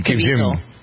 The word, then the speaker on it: Caviezel: The fellow who was in Jesus Christ, John, was it Caviezel? Is that how you pronounce his name? – Jim Caviezel. – Caviezel, yeah.